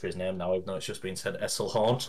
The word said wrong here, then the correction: Eisenhorn: Eisenhorn. – Eisenhorn, yes.